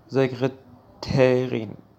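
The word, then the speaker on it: Sekretärin: لغت بعدی به معنای منشی مؤنث هستش که زکرترین تلفظ میشه.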